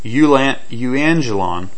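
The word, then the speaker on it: euangelion: Now evangelist is a play on the Greek word evangelon, or there's the V is actually very little pronounced, if it pronounced at all, which would be euangelon, which means the good news or something good.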